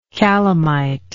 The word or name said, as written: calamitoid